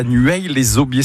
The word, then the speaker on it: Nueil-les-Aubiers: Philippe, qui habite à Nueil-les-Aubiers. C'est comme ça qu'on le prononce? – Ouais, c'est comme ça.